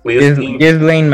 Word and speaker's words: Ghislaine: Ghislaine Maxwell.